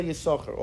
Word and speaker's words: Yisachar: And since Kabbalah's Nister is hidden, therefore we don't pronounce the second Shin. That's what I think the Rebbe quoted from his father, the Blavik. So in Lubavitch, we always just say Yisachar, always.